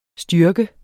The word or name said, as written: styrke